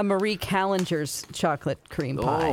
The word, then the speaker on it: Marie Callender's: Most people would call it Callender's, but my mom says Marie Callender's, and we lived in California, and I know the first restaurant is by the Queen Mary, so I think it's pronounced Marie Callender's.